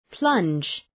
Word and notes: plunge: plʌndʒ

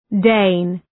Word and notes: Dane: deın

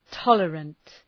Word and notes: tolerant: ‘tɒlərənt

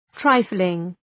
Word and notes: trifling: ‘traıflıŋ

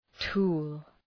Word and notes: tool: tu:l

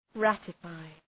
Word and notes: ratify: ‘rætə,faı

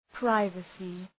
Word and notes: privacy: ‘praıvəsı

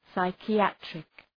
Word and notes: psychiatric: ,saıkı’ætrık

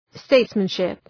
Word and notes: statesmanship: ‘steıtsmən,ʃıp

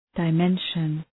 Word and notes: dimension: dı’menʃən

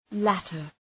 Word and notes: latter: ‘lætər

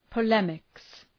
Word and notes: polemics: pə’lemıks

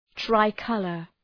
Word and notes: tricolor: ‘traı,kʌlər

